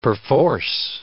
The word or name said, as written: perforce